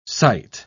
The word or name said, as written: site